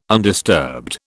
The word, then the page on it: undisturbed: British English